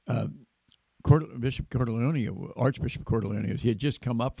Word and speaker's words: Cordileone: now the Archbishop of San Francisco, and I asked him how to pronounce his last name, uh, Cordleone, Cordleone, and he says, "I'm Italian. You pronounce every syllable, every syllable, every vowel."